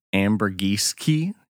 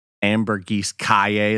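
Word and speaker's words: Ambergris Caye: Specifically going to an island called Ambergiski. Um, Ambergris Kaye is what it looks like it's spelled.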